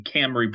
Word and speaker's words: Cymru: It was C-Y-M-R-U, not C-Y-R-M-U. – Doesn't matter. – So C-Y-M-R-U. So the Camry pronunciation, it makes a little more sense with the M before the R, but still, it doesn't make any sense. – It's still a Y making an A sound and a U making an E sound.